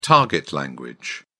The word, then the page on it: target language: British English